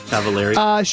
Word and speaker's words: Cavallari: Am I saying that right? Cavallari? – Cavallari.